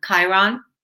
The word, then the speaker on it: Chiron: It brought to mind, actually, I don't know if I say it right, but the wounded healer, is it Kieran? – Yeah, the one, Chiron.